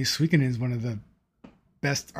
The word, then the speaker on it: Suikoden: Yes, Suikoden.